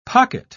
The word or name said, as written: pocket